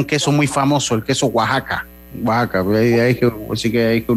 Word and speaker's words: Oaxaca: Es Oaxaca, Oaxaca, sí se le. Oaxaca se pronuncia Oaxaca.